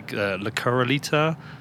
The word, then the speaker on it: corralito: I always pronounce this incorrectly. Corralito. – Yes.